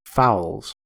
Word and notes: fowls: American English

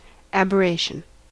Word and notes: aberration: American English

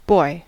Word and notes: boy: American English

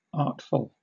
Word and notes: artful: British English

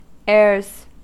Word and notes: airs: American English

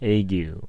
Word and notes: ague: American English

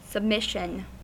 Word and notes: submission: American English

